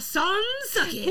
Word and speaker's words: Sans Souci: La Place Sans Souci. – Souci. Mm-hmm. Okay. So, more specifically, I am referencing the Sans Souci part.